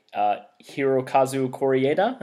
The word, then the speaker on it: Hirokazu Koreeda: So, our little sister, released by Sony Picture Classics, directed by Hirokazu Koreeda.